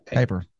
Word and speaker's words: Kiper: Who does Mel Kipler, whatever the Mel Kipler? Kipler? Kipler? – Kiper. – I knew I was going to say that wrong.